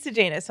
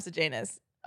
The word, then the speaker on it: Sejanus: Sid Janus. Himself. – Good job. – I did it. I said it right, guys. Okay. – He sees Sid Janus.